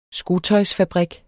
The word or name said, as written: skotøjsfabrik